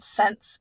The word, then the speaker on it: Cense: This is my wine and it's from somewhere around here in California and it's called Sense, but it's spelled C-E-N-S-E. So it's kind of like a mix between sense, like S-E-N-S-E, and sense, as in, you know, coins.